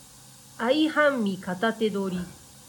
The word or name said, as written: aihanmi katate dori